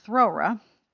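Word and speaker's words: Thowra: It's E-L-Y-N-E. Elaine Mitchell. It's about a wild horse, or Brumby, called Throra. The O is pronounced as an owl.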